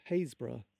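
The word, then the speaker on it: Happisburgh: Or there's a place that's called on the coast, it's spelt Happysburg, but you call it Haysborough.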